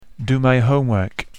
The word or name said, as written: do my homework